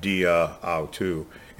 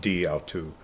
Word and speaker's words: di autou: Otherwise, you'd have dia autou. It's just pronounced di autou.